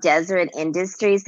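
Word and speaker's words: Deseret Industries: And he said there's something called Desert Industries there. – Mm-hmm. – Am I saying that right? It's like a Mormon. – Yes.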